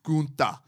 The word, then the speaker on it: Gunther: You have to say it like a German. Gunther.